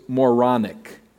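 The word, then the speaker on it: Moron (μωρόν): To the Greeks, the notion of God dying and a dead man rising from the dead was moronic. It's actually the Greek word of foolishness that we find here. Moron is how you say it in Greek.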